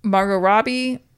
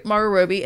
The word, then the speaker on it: Margot Robbie: And the thing is, what was really frustrating was that as much as I enjoy Margot Robbie, I never say her name right. – Margot Robbie.